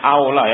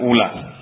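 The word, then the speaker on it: أَوْلَى: Bacanya aula ya, bukan ula. Bedanya apa aula sama ula?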